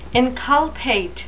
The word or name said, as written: inculpate